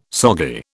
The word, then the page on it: soggy: British English